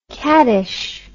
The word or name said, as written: qaddish